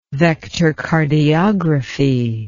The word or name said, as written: vectorcardiography